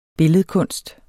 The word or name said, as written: billedkunst